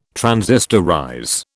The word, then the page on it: transistorize: British English